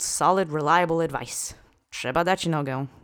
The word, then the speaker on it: nogę: And the last word is nogę. This means leg. This is a noun in its singular feminine object form. Let's say nogę together three times. Accent is on the first syllable.